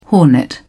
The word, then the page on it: hornet: British English